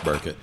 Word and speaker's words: Borchett: It's spelled Borchett. But it's pronounced Burkett.